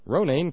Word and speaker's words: Ronayne: Funny, Ronayne kind of rhymes with insane, doesn't it?